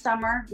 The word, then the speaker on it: Sommer: It is pronounced Summer. No, it's not Summer. There's no S at the end. It's not a Sumner. It's Summer. That's how I said it, and that's how you have to say it back.